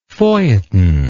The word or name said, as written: feuilletonistic